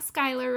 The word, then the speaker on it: Schuyler: You got Schuyler right. Whoop, whoop.